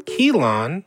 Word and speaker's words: Keelan: Featuring Keelan, Ryan, and D38. Keelan is an Irish name. I needed to make sure I pronounced that correctly, which I did.